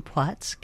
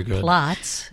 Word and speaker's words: Płock: And how about, I understand some of my neighbors spent some time in Płock. I learned how to say it this summer. It's not actually Plots. It's that L is a W sound.